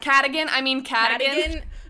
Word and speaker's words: Cadogan: Cadogan.